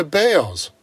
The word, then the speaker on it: Baals: Years after Hairy Balls was gone, his family changed the pronunciation of their last name to Bales.